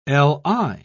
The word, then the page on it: li: British English